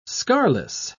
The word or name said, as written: scarless